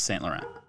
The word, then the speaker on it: Saint-Laurent: Just Saint Laurent.